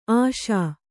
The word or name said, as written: ಆಶಿ